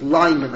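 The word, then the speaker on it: Leiman: Professor Lehman, or how does he pronounce it? Lyman, I should say.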